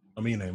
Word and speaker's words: Aminé: Wait, what did I say? – You said Aminé. – Oh, Aminé, my bad. – Aminé. – Right, regardless. – Nah, you got to say it right.